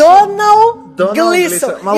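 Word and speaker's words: Domhnall Gleeson: Donald Gleeson. E eu tô falando certinho porque ela me explicou.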